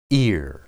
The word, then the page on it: ir: hebrew